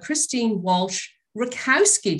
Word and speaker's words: Christine Walsh Rakowski: I'm so glad to have Christine Walsh Rakowski.